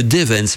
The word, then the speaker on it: Devens: Et donc, c'est dans le bois de Duvens, hein, j'espère que je le prononce bien, c'est de Devens plutôt. D-E-V-E-N, donc le bois de Devens, situé dans le canton de Neufchâtel, euh, canton suisse.